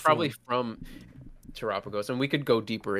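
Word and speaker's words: Terapagos: But that feels like Terrapagos.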